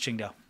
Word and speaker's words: Qingdao: And it's pronounced Qingdao.